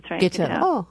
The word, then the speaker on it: Guiteau: Is it Guiteau? – Guiteau.